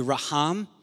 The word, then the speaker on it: raham: Over time, vowels were added, and how we would pronounce the word in English would be raham.